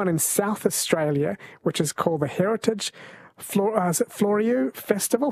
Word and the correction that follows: Fleurieu: How am I pronouncing this, Amanda? – The Heritage Fleurieu Post Festival. Fleurieu.